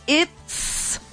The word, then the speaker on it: it's: No pronuncian la T de la palabra it. It's hot.